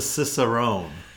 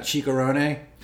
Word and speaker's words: Cicerone: It's a Cicerone. – It's a what now? – The beer sommelier is called a Cicerone. – Is it, you sure it's not Chicarone? – It has the pronunciation. Cicerone.